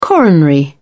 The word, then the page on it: coronary: British English